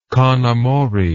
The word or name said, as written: conamore